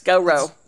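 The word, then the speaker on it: G'Reaux: Go row. – G apostrophe R E A U X. – So then you can do the Ted Lasso thing. It's pronounced Goro.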